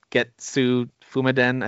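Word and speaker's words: Getsu Fuma Den: I can't pronounce it now. That Getsu Fumiden, I think is how you say it. – Yeah. Close. Yeah, I think that's pretty much.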